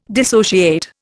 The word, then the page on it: dissociate: Indian